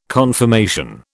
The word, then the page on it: confirmation: British English